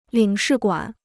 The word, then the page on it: 领事馆: lǐng shì guǎn